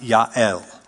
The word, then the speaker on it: Yael: That's how you would pronounce it, Yael.